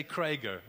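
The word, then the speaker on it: Kraeger: It's K-R-A-E-G-E-R. Kurt, how do you pronounce that? Aren't you from German descent? But say Krager, okay.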